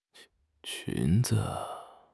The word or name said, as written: qun2 zi5